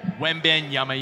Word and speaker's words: Wembanyama: All eyes on Victor Wembanyama, yama, yama, yama.